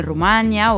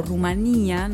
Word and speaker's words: Rumanía: Estará presentándose en Rumania o Rumanía, no sé cómo se dice. – Las dos está bien.